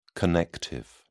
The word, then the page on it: connective: British English